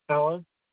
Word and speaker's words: Alan: Alan. – Your first name? How do you spell it? – A-L-A-N.